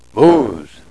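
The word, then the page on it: mooz: Nishnaabemwin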